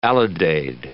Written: alidade

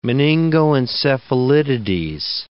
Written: meningoencephalitides

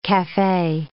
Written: cafe

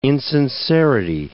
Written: insincerity

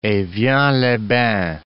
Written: evian-les-Bains

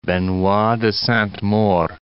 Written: Benoit de Sainte-Maure

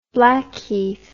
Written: Blackheath

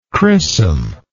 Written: crissum

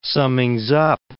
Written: summings-up

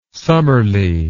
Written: summerly

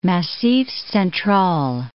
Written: Massif Central

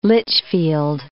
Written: Lichfield

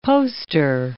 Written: poster

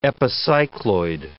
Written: epicycloid

